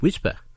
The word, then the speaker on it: WSPR: This Wednesday night is our February Forum Night, and it will be all about WSPR, pronounced Whisper, which stands for Weak Signal Propagation Reporter.